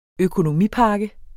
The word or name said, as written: økonomipakke